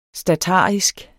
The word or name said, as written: statarisk